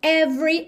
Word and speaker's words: every: Então, every, lembra de não pronunciar o E do meio, every night in, ela ligou com rarara, night in, every night in my dreams.